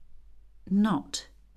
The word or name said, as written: note